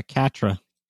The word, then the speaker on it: CATRA: the Catra, Carta, I never know how to pronounce it. – Yeah, Catra.